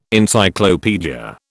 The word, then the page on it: Encyclopaedia: British English